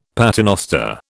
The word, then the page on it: paternoster: British English